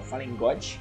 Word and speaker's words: Fallen God: Ah, falei certo agora, Fallen God.